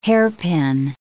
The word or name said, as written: hairpin